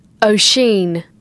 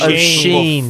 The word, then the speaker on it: Oisin: We're worried about Oisin, which is the pronunciation. – Take your moment. – Thanks. We had, strangely enough, an Irish fan called Paddy email in. – Oshin. – Yeah. He's saying a little O-sheen. – Bit with a bit like.